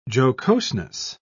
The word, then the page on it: jocoseness: British English